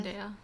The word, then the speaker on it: Zendaya: How do you say it? Zendaya. Zendaya.